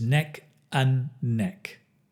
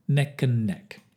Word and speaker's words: neck and neck: Notice the pronunciation is neck and neck, not and, just an, neck and neck.